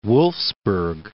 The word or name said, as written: Wolfsburg